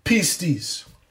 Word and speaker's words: pistis: Our English New Testament word faith in this context comes from the Greek New Testament word pistis, pronounced P-E-E-S-T-E-E-S, pistis.